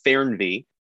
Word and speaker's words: Fernweh: It's spelled Fernweh, and it means, it's pronounced Fernweh, and it means the longing for faraway places, or the opposite of homesickness.